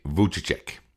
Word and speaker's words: Vujicic: And this next one is Nick Vujicic. That's spelled V-U-J-I-C-I-C.